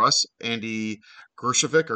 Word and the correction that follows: Greskovic: How do you say his name, Chris? – I believe it's Andy Greskovic. – Yeah. Greskovic, yes.